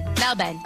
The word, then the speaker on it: Melbourne: It's not Melbourne, it's Melbourne. Or is it Melbourne? – Melbourne. Or Melbourne. Oh, Melbourne. Apparently they don't pronounce the R. – Okay. So no R's.